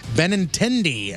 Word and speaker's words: Benintendi: Boston's Andrew Benintendi, I think is how you say his name. It's probably completely wrong. – Nope, nailed it, dude.